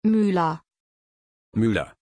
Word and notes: Mylah: German

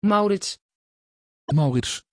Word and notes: Maurits: Dutch

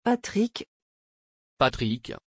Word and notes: patrik: French

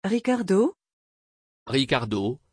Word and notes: Ricardo: French

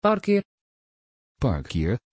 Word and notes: parker: Dutch

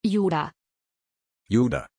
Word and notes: juda: German

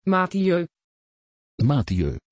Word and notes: Matthieu: Dutch

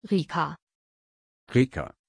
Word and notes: Rica: German

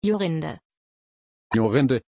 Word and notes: Yorinde: German

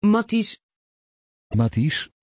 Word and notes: Mattys: Dutch

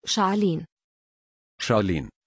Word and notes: charleen: German

